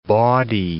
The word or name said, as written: bawdy